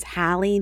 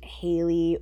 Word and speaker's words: Haley: We only found out that it's pronounced Haley, or not even pronounced. It is Haley. It's not Hallie. And so you'll just have to forgive us for that and pretend like we're saying Haley because it's Haley, not Hallie.